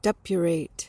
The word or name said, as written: depurate